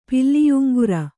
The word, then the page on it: ಪಿಲ್ಲಿಯುಂಗುರ: Kannada